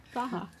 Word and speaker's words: Praha: Praha. – That's what they told us it was, like, pronounced as. – Oh, really? – Yeah. Praha, not Prague. Yeah, it's like Prague is like how we say it, or like we read it, and then.